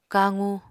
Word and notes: kaqu: Apatani

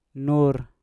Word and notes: nur: Mpur

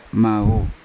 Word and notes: mavu: Yawa

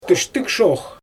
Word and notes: tɯʃtɯɣʃox: Xyzyl

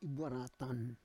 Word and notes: ibuaratan: Roon

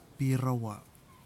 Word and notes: pirawa: kwamera

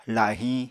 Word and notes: lahiñ: apatani

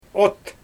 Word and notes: ot: Xyzyl

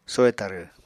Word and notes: soy tarw: Apatani

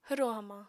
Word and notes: hwro hamañ: apatani

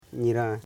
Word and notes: nyiran: nyi.ran; Koro

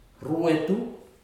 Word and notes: ruetu: futuna aniwa

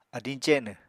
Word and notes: adiñ cenw: Apatani

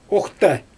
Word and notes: oGtA: Xyzyl

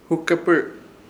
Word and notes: hukepɨr: Ishir Chamacoco

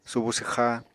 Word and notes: subu swxa: Apatani

